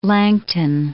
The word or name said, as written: Langton